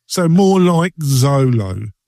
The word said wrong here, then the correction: Zoho: Do you mean Zoho? – Pardon? – Yeah. Zolo. Zoho. Zoho. – Yeah, Zoho. Sorry, it's my pronunciation.